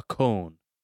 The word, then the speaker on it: koan: Am I saying this right? – K-O-A-N. Con.